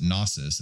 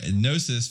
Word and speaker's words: Gnosis: And Gnosis, G-N-O-S-I-S. I've heard people pronounce it Gnosis, and it's like a cheese grater on me. It's Gnosis, like as a Gnostic, and Gnosis, the Greek word for knowledge and wisdom and stuff like that.